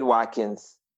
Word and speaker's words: Watkins: For Facebook, my name, David Watkins, is the best way to follow me on Facebook or on Twitter.